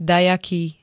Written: dajaký

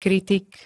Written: kritik

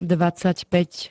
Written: dvadsaťpäť

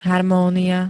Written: harmónia

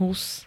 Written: hus

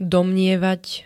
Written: domnievať

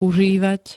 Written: užívať

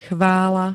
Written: CHVÁLA

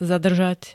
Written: zadržať

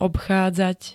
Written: obchádzať

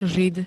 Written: žid

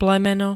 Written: plemeno

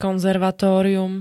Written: konzervatórium

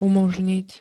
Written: umoznit

